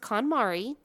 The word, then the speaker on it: KonMari: And so she I could be wrong on that, but I think it's pronounced KonMari.